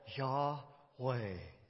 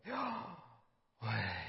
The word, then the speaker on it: Yahweh: And how you say that is we pronounce that Yahweh. Yah. Or you could go Yahweh.